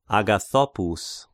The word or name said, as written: Agathopous